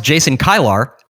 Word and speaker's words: Jason Kilar: And just ask Jason Kilar. That's the correct pronunciation.